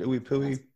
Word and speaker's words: IUPUI: Yeah, so my individual, it's kind of weird because I went to IUPUI in Indianapolis, so everyone's like, how do you say that? Is it Ooey Pooey? – That's what that means.